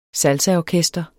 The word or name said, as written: salsaorkester